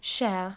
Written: share

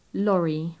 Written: lorry

